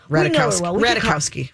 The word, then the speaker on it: Ratajkowski: Emily Ratajkowski. Is that close enough, do you think? – Close-ish, yeah. – I don't think you pronounce Ratajkowski. I don't think you pronounce the J. – Yeah, Ratajkowski.